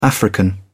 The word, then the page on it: african: English; British English